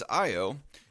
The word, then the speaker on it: Ayo: How do you pronounce that guy's name? Is it Ayo? – No. – Actually, it's Ayo.